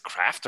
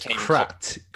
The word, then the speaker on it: Kratt: Is it Craft or Kratt? Oh my gosh. – It's Kratt. – Kratt. – Kratt. There you go.